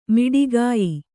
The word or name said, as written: ಮೋಟಕೆಯ್